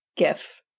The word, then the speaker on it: GIF: Is it pronounced GIF or JIF? – GIF. Yeah, all right. GIF.